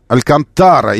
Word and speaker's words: Алькантара: Значит, по Википедии Алькантара. Алькантара, именно на тара ударение.